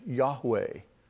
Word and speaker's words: Yahweh: Our best guess, our best understanding of that is the pronunciation Yahweh. Y-A-H-W-A-Y.